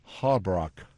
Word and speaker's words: Holbrook: Un día como hoy de 1912 en Holbrook, Condado de Navajo, en Arizona. ¿Viste cómo lo pronuncié? – Estás aprendiendo. – Anoche llamé a Rosana y dije, ¿cómo se dice esto? Y me dijo, Holbrook.